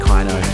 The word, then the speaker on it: Kino: It's called Kino with an I, not with an E. So, Kino. Kino? Probably. It's probably pronounced Kino.